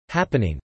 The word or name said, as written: happening